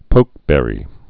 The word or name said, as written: pokeberry